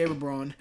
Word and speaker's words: Eva Braun: Obviously Eva Braun, because that's how it's spelled.